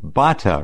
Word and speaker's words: Batta: His name was Rico, R-I-C-O, and last name B-A-T-T-A, Bata, right? – That's correct.